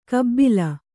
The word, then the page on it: ಕಬ್ಬಿಲ: kabbila